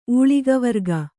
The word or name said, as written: ಊಳಿಗವರ್ಗ